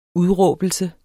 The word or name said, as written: udråbelse